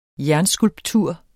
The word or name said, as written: jernskulptur